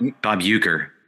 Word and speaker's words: Uecker: Bob Wecker? – Bob Uecker.